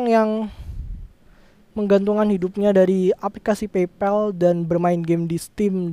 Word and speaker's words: PayPal: Ada kayak PayPal, PayPal bukan PayPal ya, bacanya PayPal.